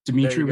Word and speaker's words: Dimithri: The just like the H in my name, it's silent. Dimithri with an H.